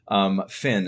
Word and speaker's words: Fenn: Fenn is how I normally say it.